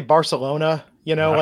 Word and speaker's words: Barcelona: You do it with. It's like the little lisp. – Barcelona. – Yeah. – It's like a TH.